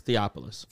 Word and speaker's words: Theophilus: Yes, Theophilus.